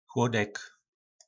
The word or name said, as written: chlodek